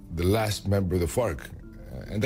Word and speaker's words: FARC: better known and carefully pronounced as FARC, was flawed and muddled, but then pretty much all peace agreements are.